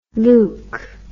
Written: leuk

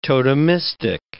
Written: totemistic

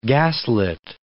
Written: gaslit

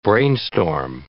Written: brainstorm

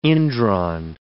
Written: indrawn

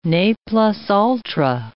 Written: ne plus ultra

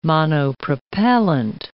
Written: monopropellant

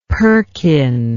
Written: perkin

